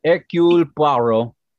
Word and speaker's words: Hercule Poirot: And of course, my Belgium reference was to Hercule Poirot. I can never pronounce it. – Hercule Poirot. – I was close.